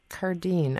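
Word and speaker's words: Cardin: But I don't have, I was thinking about that after you were saying the two syllables, but I think Cardine, I think the emphasis is on the second syllable.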